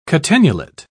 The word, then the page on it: catenulate: British English